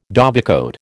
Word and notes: dovecote: American English

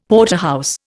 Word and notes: Porterhouse: Indian

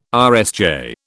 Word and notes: RSJ: British English

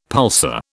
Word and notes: pulsar: British English

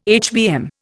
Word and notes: HBM: Indian

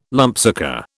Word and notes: lumpsucker: British English

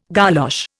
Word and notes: golosh: Indian

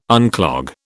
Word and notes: unclog: British English

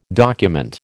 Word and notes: document: American English

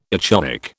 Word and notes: echoic: British English

